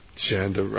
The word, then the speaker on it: Janda: Janda rhymes with panda, so it's easy to remember.